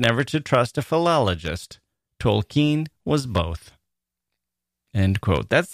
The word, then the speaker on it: Tolkien: Did I say Tolkien before? It's an old habit. That's how I grew up pronouncing his name. I believe it's Tolkien.